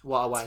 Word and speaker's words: Huawei: Hawaii. – Huawei P30 Pro.